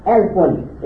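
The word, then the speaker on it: Elfun (ألف): And the word, the first word to your right is pronounced Elfun. It means 1,000.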